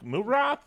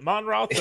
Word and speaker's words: Monroth: I was like, is it like Moonroth? Is it Monroth? So now we know.